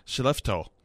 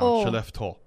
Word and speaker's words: Skellefteå: But because it looks like it should be Skellefteå. But it's Skellefteå.